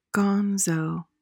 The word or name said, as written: gonzo